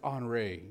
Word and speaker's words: Henri: I think if I pronounce it like French, it's Henri, you know what I'm saying?